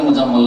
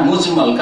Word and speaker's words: মুজমাল: ভুল করে অনেক উচ্চারণ করে মুজাম্মাল না, একই রকম করে উচ্চারণ করে কিন্তু আসলে মুজাম্মাল না, মুজমাল।